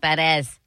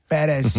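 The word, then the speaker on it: Pérez: Parents, Eva Perez Suarez. That's my mother's maiden name. – I believe that's pronounced Perez. – Perez.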